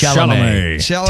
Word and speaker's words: Chalamet: Timothy Chalamet? – Chalamet.